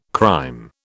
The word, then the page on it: crème: British English